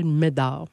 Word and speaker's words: Médard: M-E-D-A-R-D. – C'est spécial. – C'est comme le nom de famille Bédard, mais prononcé, je m'appelle Médard. – Et là, Médard.